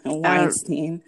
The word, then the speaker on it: Weinstein: Which means in that, in regards, since I just brought up Harvey Weinstein, I don't know how to say his name. I really don't. – Weinstein.